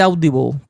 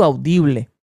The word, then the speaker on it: Audible: En inglés se dice Audible. Ya sé yo cómo se dice, lo mío no es en inglés. Pero en español yo le digo Audible.